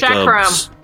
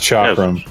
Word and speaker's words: chakram: The phoenix circles are one form of charcam. A charcam is a round weapon, kind of like chakram. – Yeah, it's pronounced chakram.